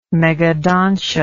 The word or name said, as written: megadontia